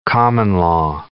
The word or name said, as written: common-law